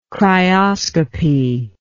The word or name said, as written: cryoscopy